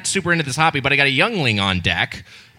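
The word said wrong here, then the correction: Yuengling: Did I say it wrong? Just one person say it. How do I say it? Yingling. Okay. I said it Youngling like Anakin describing the young Jedi.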